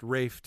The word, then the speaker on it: Ralph: And Ralph is pronounced Rafe to rhyme with safe.